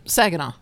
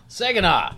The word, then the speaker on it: Saginaw: Saginaw. – Saginaw.